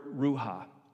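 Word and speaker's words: ruach: That word breath in the Hebrew is the word ruha. Pronounced a little differently. My Hebrew is a little off, right? Ruha. Ruha is the same word in the Hebrew for spirit.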